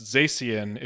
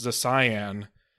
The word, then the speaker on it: Zacian: I mean, even in the latest generation, just the other day, somebody proposed to me that it should not be pronounced Zacian. It should be pronounced Zacian, because it would be cyan and magenta.